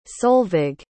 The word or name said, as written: solvig